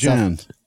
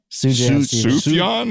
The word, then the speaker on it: Sufjan: Sufjan. – Sufjan is how I usually hear it.